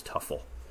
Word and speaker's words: Teufel: I would pronounce this Tim Teufel. Teufel is the German word for devil. And so when I look at that, I would call him Tim Teufel. And I think that actually Brian once told me that the correct pronunciation was Tuffel. – Or the pronunciation that he went by was Tuffel. Whether it was correct or not, it was the one that was adopted throughout his baseball career.